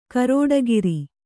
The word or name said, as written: ಗರುಡಿಕಾರ